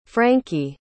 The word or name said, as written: franky